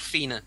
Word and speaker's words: Fianna: The Fianna, or as they're spelled, Fianna, but that's not how it's pronounced. It's called Fianna.